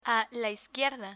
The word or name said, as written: a la izquierda